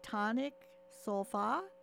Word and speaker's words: Tonic Solfa: Greg, I hope I said that name correctly. Tonic Solfa? – That's actually the best I've heard it pronounced for quite some time.